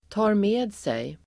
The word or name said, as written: tar med sig